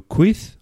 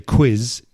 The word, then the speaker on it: quiz: But if I go quith, un quith, and they go, ah, yeah, un quith. And others have no idea what I'm talking about. So some people recognize that word if you pronounce it in a Spanish way.